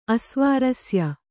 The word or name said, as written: ಅಸ್ವಾರಸ್ಯ